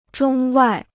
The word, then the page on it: 中外: zhōng wài